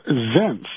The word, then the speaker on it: Zients: I believe his name is Jeff Zients. It's spelled Z-I-E-N-T-S. Jeff Zients.